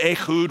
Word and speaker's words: Ehud: Again, the Israelites did evil in the eyes of the Lord now that Ehud was dead. I think that's the way you pronounce that in Hebrew, is Ehud. I would read it Ehud.